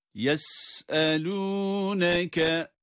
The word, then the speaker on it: Yes'elûneke: Yes'elûneke. – Sin ince okunuyor. Yes ve ses akıyor. Evet, sesin akarak çıkması var.